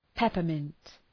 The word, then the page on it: peppermint: ‘pepər,mınt